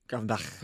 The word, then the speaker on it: Gravenberch: Okay, say it properly. – Gravenberch. – Gravenberch. – Gravenberch. – Yeah. Gravenberch. Gravenberch. He's perfect. Gravenberch.